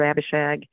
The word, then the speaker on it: Abishag: I just finished a series on the little book, Dear Abishag.